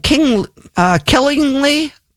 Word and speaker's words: Killingly: Let's go to who is calling from Killingly, Connecticut. Am I saying that right? – You certainly are. That's correct.